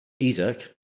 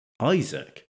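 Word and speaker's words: Isak: So I pronounced Alexander Isaac as Alexander Isaac.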